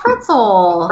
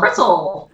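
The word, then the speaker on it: Fritzl: It sounds like a strudel. You're fine. – Really? A strudel? – Yeah. – Oh, Fritzel. – Fritzel.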